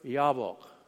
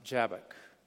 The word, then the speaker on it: Jabbok: And they head that way and they arrive to the edge of the river Yabbok. We would pronounce it Jabbok.